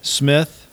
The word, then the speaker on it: Smith-Vaniz: Smith-Vaniz. – Smith-Vaniz. – It looks like Vaniz or Vaniz. I've heard it like 15 different times. – So the stress of the pronunciation on Vaniz is on the Nez.